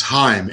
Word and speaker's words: Heim: John, how do you say your last name? – It's Heim.